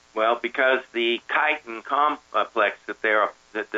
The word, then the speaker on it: chitin: it's spelled C H I T I N, but it's, spelled, it's said chitin that material, the material that the mushroom is made of, is exactly the same material that a crab and lobster shell consists of.